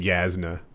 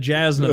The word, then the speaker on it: Jasna: I think that Jay's pronounced like a Y. I had no idea her name wasn't Yasna, but carry on.